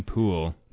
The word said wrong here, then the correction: Hoole: No, Hoole. – Hoole. – Rhymes with pool. – That's right. – Or cool.